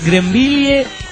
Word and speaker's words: Greenville: Pero esa aclara, Greenville, pronunciación. – Greenville.